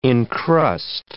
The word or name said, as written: encrust